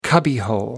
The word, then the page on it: cubbyhole: American English